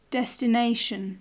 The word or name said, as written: desination